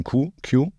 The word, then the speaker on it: queue: Q-U-E-U-E? – Ja. Yeah. – Das Witzige daran ist, dass dieser Buchstabe eigentlich ausgesprochen wird, wie eigentlich nur wie ein Q, Q. Und die anderen 4 Buchstaben dahinter sind einfach nur silent.